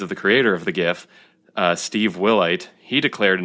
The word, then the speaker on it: GIF: Uh, GIF, as people may or may not know, stands for Graphics Interchange Format, and graphics has a hard G sound, so that should translate to the GIF acronym.